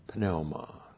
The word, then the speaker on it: pneuma: That's P-N-E-U-M-A. Pneuma. Now, a lot of you call it pneuma, but there's no silent letters in the Koine Greek. So, no great Greek would say pneuma. He said pneuma.